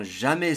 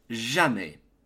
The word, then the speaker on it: jamais: Je ne comprends jamais ces leçons. I never understand these lessons. Let's begin. Pronunciation. The pronunciation of never in French is jamais. Jamais.